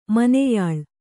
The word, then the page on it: ಮೊನೆಯಾಳು: moneyāḷu